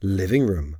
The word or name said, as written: living room